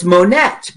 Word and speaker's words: Monette: You don't pronounce the last letter, so it's Monette, because it's M-O-N-E-T-T-E.